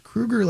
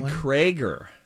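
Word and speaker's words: Kroeger: In that September interview, he said it's pronounced Krueger like Freddy Krueger. – Dang it. – So that's a point for Donna. – And I said Krager.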